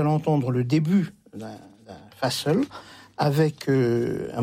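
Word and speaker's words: fasıl: Alors, vous me rectifierez pour la prononciation. – Je vais vous proposer d'écouter un fa-sol. – Oui, fa-sol. Ça veut dire concert en turc, si on le dit comme il faut.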